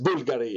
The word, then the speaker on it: Bulgari: It's Bulgari. Has nothing to do with the country Bulgaria. Nothing. Nothing whatsoever. Okay. But if you want to pronounce it in the original name, it's Bulgari in Italian.